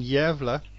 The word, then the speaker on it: Gävle: This is the Pandora event in Gävle.